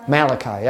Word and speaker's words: Malachi: Rhymes with Haggai, but it has more syllables. Bum, bum, bum. Bum, bum, I. – Malachi? – Malachi, yeah, that's the song.